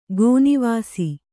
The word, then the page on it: ಗೋನಿವಾಸಿ: Kannada